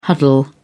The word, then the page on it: huddle: British English